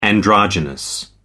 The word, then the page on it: androgynous: American English